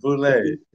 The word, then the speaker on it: Boulet: Boulet.